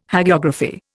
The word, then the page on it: Hagiography: Indian